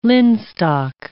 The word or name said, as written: linstock